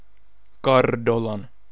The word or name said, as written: Cardolan